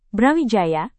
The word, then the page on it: brawijaya: Indonesian